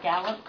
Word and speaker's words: Gallop: I'm Sarah Gallup, co-director of Government and Community Relations at MIT. My last name is spelled G-A-L-L-O-P, and it's Sarah with an H.